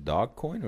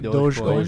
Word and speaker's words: Dogecoin: And uh, uh, Henry was asking, how about is it pronounced dog coin or Dog Doge Dogecoin.